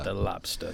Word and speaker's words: Da Lobsta: And by the way, spell it D-A-L-O-B-S-T-A, De Lobster. – De Lobster, yes, very good.